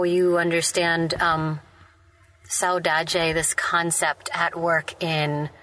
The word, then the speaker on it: saudade: So a question for Bio and Giordi is, I've heard you two use this term now a couple times of saudade. Am I pronouncing that correctly? Mm-hmm.